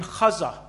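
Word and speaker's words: Chuza: So when you trace who Joanna is, she was the wife of a man named Chaza. I think that's how you pronounce it, isn't it, Hebrew?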